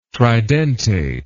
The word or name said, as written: tridentate